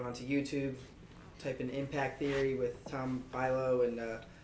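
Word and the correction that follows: Bilyeu: Go- what is it? Bill you. Bill you. Yeah, I uh, mispronounced that, but.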